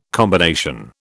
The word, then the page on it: combination: British English